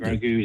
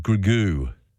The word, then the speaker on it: Grogu: A Mandalorian and how do you pronounce it? Grogu. – Grogu, yeah, Grogu. Just call it Baby Yoda.